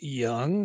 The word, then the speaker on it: Jung: Josh Jung of the Texas Rangers, last name spelled J-U-N-G, of course, but pronounced Young.